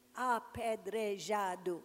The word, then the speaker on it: apedrejado: How do you say it? Apedrejado. Apedrejado. Assim. Ouch! Assim, pedrejado. Algumas palavras são diferentes, algumas são inventadas.